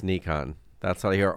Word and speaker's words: Nikon: I heard that Nikon Japan said the correct way is, phonetically spelling here, Nikon, but they are also happy for people to say Nikon in third place or Nikon, rhyming with kick on.